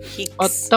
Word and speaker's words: Hicks: En principio te voy a decir quién es el doctor Hitch, Hicks. Thomas Hicks. Hicks. Gracias por esas correcciones en la pronunciación en inglés, que para mí es fatal. – Sí, no es Hitch con CH, es Hicks. O sea, H-I-C-K-S.